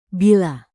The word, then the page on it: bila: indonesian